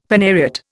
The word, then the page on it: phanariot: Indian